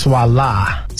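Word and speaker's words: Swalla: It's actually pronounced Swala. – Voila. – Yeah, like voila, voila.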